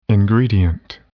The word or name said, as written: ingredient